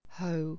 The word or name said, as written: hoe